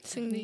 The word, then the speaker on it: Seungri: his name is Seungri. Did I pronounce that right? – Seungri, yeah, Seungri.